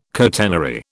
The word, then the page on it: catenary: British English